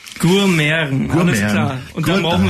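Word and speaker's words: Gurmiaren: Gourmeren. – Gourmeren. – Also G-U-R, ja, okay. Und dann M, das Schöne, M-I-A-R-E-N, das I-A wird wie Ä ausgesprochen.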